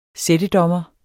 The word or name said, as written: sættedommer